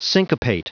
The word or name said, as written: syncopate